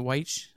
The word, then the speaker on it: Wyche: Is it Wyche? How do you say it? – Yeah, that's how you say it.